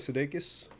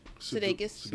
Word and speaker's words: Sudeikis: Jason Sudeikis from Where the Millers was. How do you say his last name? – Sudeikis.